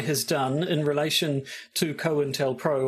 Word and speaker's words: COINTELPRO: I guess it was Cointelpro. There we go. We now have a de facto pronunciation of the program. It is the Cointelpro.